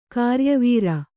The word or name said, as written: ಗರಿಯವರೆ